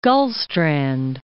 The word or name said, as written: Gullstrand